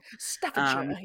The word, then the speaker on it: Staffordshire: Staffordshire, yeah.